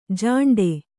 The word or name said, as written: ಝಿಂಟಿ